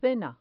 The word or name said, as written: cena